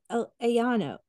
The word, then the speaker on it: Alleno: So then we move into the main course, which is breasty chicken with corn and a porcini mushroom gratin cooked by Yannick Alano, or Alano, A-L-L-E-N-O.